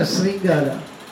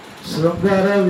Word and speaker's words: Srungara: It's not Sringara. Krishna is the only exceptional word. That we pronounce Krishna. But others and all not allowed. Srungara, we should call it as only Srungara. Many people they say Sringara. Because Krishna will tell Sringara. In Sanskrit, they don't pronounce Sringara. Srungara. For Telugu people, there is no problem. We never say Sringara.